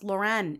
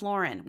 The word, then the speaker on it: Lauren: Lauren Bush Lauren, whose last name is not pronounced Lauren. It's Lauren.